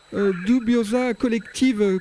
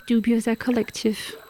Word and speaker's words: Dubioza Kolektiv: What does mean the name of your group Dubiosa Collective? – There is a word in English, and I guess in French as well. Dubious, like suspicious. Dubious Collective. So it's just a Bosnian pronunciation of this word.